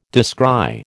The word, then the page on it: descry: American English